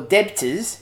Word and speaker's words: debtors: Debtors, or debtors, if you were to see it on a page, silent B's, never trust a word with a silent B in it.